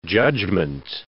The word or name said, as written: judgement